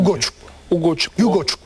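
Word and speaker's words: Ugochukwu: Yani Dani Olmo, Foden finalde Ogocukwu olunca, doğru mu telaffuz ettim? – Ugoçuk hatta.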